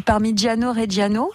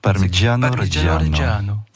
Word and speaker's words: Parmigiano-Reggiano: Alors ce consortium du Parmigiano-Reggiano, je dis bien ou pas? – Oui, c'est parfaitement, parfaitement dit. – Parmigiano-Reggiano.